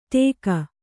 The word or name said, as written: ಟೇಕ